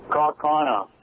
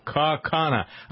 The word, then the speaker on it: Kaukauna: Kaukauna. – Kaukauna. I like it, though, for purposes of my own life and the last bit that we did. How about we call it Kaukauna?